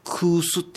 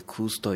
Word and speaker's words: 古酒: 地下はいわゆるその空酢っていう、古酒と書いてね、古酒と書いて空酢というんですが、